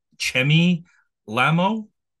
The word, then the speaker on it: Chemi Lhamo: Her name is, I think you pronounce it, Chemi Lhamo? – You got it. Chemi, like chimichangas.